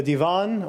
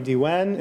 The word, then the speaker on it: Divan: The Divan, or Diwan in Arabic. A Divan would be the Persian pronunciation.